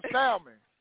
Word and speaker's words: salmon: I want some salmon. I pronounce the L. – How you? It, so it, you don't pronounce the L? – No.